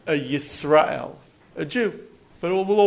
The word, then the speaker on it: Yisrael: Yisrael with a Y. That's the Hebrew way to say it.